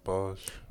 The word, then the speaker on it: Boj: How you say his name again? Boj, Boj. – Oh yeah, Boj.